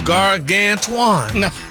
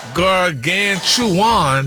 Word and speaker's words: gargantuan: I sound like you missed a syllable there, sir. – Oh, gargantuan.